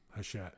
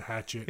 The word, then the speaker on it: Hachette: So, it took me like a whole month to figure out how to say their name, Hachette, as opposed to Hatchet.